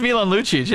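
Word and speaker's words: Milan Lučić: Milan Lucic? – Yes, I think that's how Luch? – You pronounce it. What, am I supposed to say it differently? – I don't know. It's Milan Lucic, yeah.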